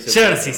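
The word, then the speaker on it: Saoirse: Jersey se pronuncia, ahí está, Jersey Ronan y Lucas Hedges.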